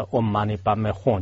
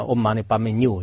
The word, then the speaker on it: Om Mani Padme Hum: 原来就是说是一个人就是念的 Om Mani Padme Hum，就是然后最后他念成了 Om Mani Padme Nu，就是然后像后来就是有一个人听到就是他的念错了，就是后来去纠正的时候呢，